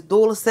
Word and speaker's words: Dulce: And I respected it because I correct people on how to pronounce my name all the time. Oh, you're saying your name wrong? I'm like, no, it is Dulce. It is Italian, it's Spanish, not Italian.